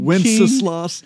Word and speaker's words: Wenceslas: Wenceslas. – Good Wenceslas.